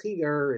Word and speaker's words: Schigier: But let me read you something from, there's a rabbi named, in Hebrew the name is pronounced Chiger, it's pronounced in English, it's written S-C-H-I-G-I-E-R.